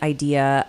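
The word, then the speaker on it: Aydea: So Idea, I'm pronouncing it Idea, but it's not spelled exactly like that. It's A-Y-D-E-A.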